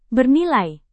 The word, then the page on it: bernilai: Indonesian